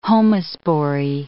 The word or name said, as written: homospory